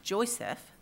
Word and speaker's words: JOICFP: Now, with the acronym JOICFP, which is pronounced as JOICEF,